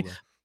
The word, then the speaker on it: Zamula: They have Zamgula, I don't know how to pronounce his name properly. Zamula.